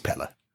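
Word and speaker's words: Pelle: there is one of the characters who calls him Pele in the film, but when he pronounces it, it's Pella.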